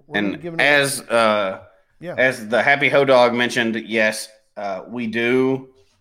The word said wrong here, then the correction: Hodag: Hodag, not Dog. I said that wrong.